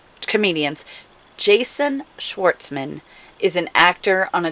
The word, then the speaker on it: Schwartzman: Jason Schwartzman. – Okay, that is his name. Jason Schwartzman all live together. Jason Schwartzman.